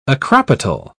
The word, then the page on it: acropetal: British English